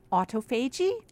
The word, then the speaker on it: autophagy: autophagy. – Autophagy? – Autophagy. – Yeah, the word would mean you're eating yourself. – Yeah. – So is that the autophagy? Is this a good autophagy or bad autophagy? – The good one.